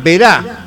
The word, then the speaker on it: Verá: En el bar Vera dice que la escuchaba, Sarís. 8 de octubre y Vera. Ah, Verá, con acento en la A.